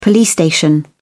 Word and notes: police station: British English

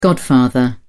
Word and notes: godfather: British English

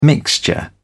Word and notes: mixture: British English

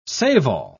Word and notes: save-all: British English

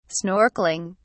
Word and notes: snorkeling: British English

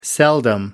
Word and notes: seldom: American English

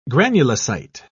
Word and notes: granulocyte: British English

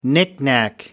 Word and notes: knickknack: British English

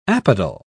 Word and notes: apodal: British English

